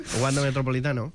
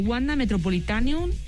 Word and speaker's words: Wanda Metropolitano: S'ha dit que és complicat a vegades de pronunciar, però no és el primer cop que surt en una pífia, que és el Wanda Metropolitan... – Sí, Wanda Metropolitano. – Ah, exacte. Aquí sí.